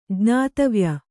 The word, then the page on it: ಜ್ಞಾತವ್ಯ: jñātavya